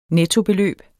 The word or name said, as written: NETTO